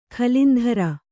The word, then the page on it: ಕುಲೇಂದ್ರ: Kannada